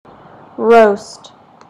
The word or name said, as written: roast